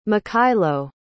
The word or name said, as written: mykhailo